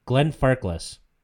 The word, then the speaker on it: Glenfarclas: Glenfarclas? – Glenfarclas.